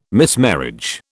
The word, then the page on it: mismarriage: British English